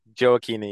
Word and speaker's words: Gioacchini: Is that how you pronounce it? Gioacchini? – Joachini, yes, Gioacchini.